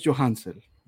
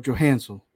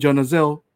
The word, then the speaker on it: Johanzel: And in school, I remember my teacher saying Jonazel. They never got the name right.